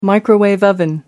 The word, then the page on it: koala bear: British English